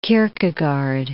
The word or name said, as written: Kierkegaard